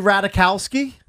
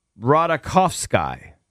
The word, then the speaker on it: Ratajkowski: Emily Ratajkowski. – Emily, it's pronounced Radakowski.